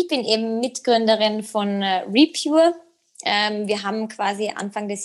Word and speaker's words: Repure: denn du bist Mitgründerin und Projektmanagerin von der Repure App.